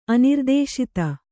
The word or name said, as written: ಅನಿರ್ದೇಶಿತ